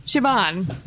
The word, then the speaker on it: Siobhan: Siobhan.